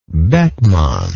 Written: beckmann

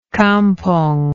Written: campong

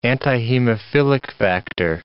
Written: antihemophilic factor

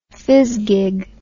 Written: phizgig

